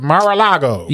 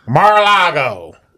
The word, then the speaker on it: Mar-a-Lago: Is that how you say it? Mar-a-Lago. – Yeah. – Mar-a-Lago.